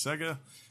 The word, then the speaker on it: Sega: Sega and Atlus characters. I should have said it right. Sega.